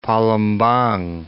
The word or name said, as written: Palembang